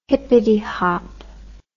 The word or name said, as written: hippety hop